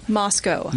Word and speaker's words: Moscow: Moscow.